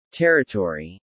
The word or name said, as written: Territory